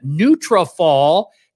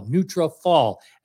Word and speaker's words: Nutrafol: But right now, I got to tell you what's going on with our friends at Nutrafol. Took me a minute to be able to learn how to spell it, but I got it. It's N-U-N-U-T-R-A-F-O-L, Nutrafol, as in follicles.